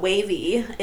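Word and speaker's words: WayV: We love them all, but Wavy is the first one. Man. It's the Chinese unit, and it's spelled W-A-Y and then V. – Not Wave. – Yes. So, this is Wavy.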